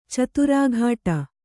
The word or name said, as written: ಚಿತ್ರಕುಟಿ